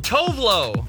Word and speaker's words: Tove Lo: Okay, T-O-V-E-L-O. – Oh, Tovlo.